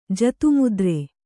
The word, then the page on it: ಜತುಮುದ್ರೆ: jatu mudre